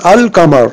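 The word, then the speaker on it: al-qamar: On prononce « al-kamar ». Vous voyez ?« al-kamar ». Il y en a le « lam », ça reste intact. Ça ne disparaît pas dans « kaf ».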